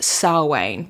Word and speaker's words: Samhain: It's spelled Samhain for anyone who wants to Google it, but it's pronounced Samhain, which, like, that's Gaelic for you.